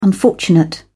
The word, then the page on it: unfortunate: British English